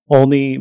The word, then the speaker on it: Olney: So you grew up in Olney, Maryland.